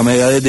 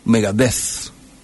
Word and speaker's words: Megadeth: La TH, ¿cómo se lee? ¿Cómo se pronuncia? A ver, bájame la cortina a 0, por favor. – ¿Cómo? – ¿Borrosita? – D. TH es D. – Yo pensé que era como una Z. Megadeth. – Nah, eso en España.